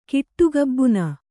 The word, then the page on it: ಕಿಟ್ಟುಗಬ್ಬುನ: kannada